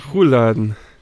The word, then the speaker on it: Rouladen: Sogenannte Rollladen. Die schmeckt auch, der schmeckt auch mit Sauerkraut am besten. – Ja, du hast falsch ausgesprochen, sag ich auch. Rouladen.